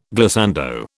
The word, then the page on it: glissando: British English